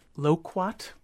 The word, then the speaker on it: loquat: Someone had their loquat, a mm-hmm. Japanese plum tree, am I pronouncing that right? – Mm-hmm.